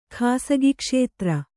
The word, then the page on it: ಖಾಸಗಿಕ್ಷೇತ್ರ: khāsagi kṣētra